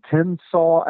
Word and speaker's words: Tensaw: Fort Mims State Historic Site in Tensaw, Alabama. That's spelled T E N S A W, just like it sounds.